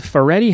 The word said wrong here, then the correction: Fioretti: how do you say his last name there? – Fioretti. – He is the lion, and who plays the Tin Man? – His sister, Sophia Fioretti.